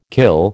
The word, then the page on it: kill: American English